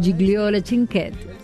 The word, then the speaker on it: Gigliola Cinquetti: Sí, Gigliola o Gigliola. Yo la conozco como Gigliola. Sí. Se escribe Gigliola. Pero es Gigliola Cinquetti.